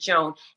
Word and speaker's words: Joan: Cause now I say, oh, I pronounce it Joan.